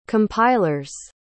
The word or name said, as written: compiler's